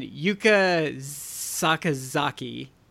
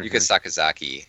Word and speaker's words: Yuka Sakazaki: So Riho defeated Nyla Rose and Yuka Sakazaki.